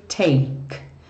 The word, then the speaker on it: take: Every breath you take. Olha aqui, take, sem pronunciar o E final, nada de falar take, mesmo ele estando escrito. Eu tô marcando para você aqui, ó, que esse E não tem som.